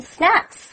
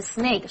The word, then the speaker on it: snack: Right, next Chris says, In the meantime, let's order some drinks and snacks. – 当然了，不要忘了他们在咖啡厅，所以 Chris 就说哎呀没有错，在这个同时呢我们应该要来一点饮料还有 snacks, 就是点心哦，注意这个字的发音是 snack, 不要发成 snake, 蛇哦。OK, 那 in the meantime 就是同时的意思。